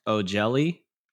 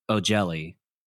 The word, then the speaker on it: O'Jelly: so it feels like it should be pronounced O'Jelly, like it's it's an Irish or Scottish thing.